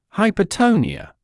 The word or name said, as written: hypotonia